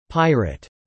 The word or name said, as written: pirate